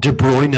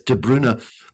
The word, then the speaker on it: De Bruyne: I was just going on YouTube and seeing an interview with him and finally learning how to pronounce this guy's name, Kevin De Bruyne. Not De Bruyne, as everyone says, De Bruyne.